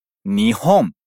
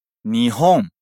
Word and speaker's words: Nihon: Nihon. Nihon. E, né, legal. E ela junta com a partícula ni, que indica a direção, ou seja, ela disse para o Japão. Repete aí.Nihon ni. Nihon ni.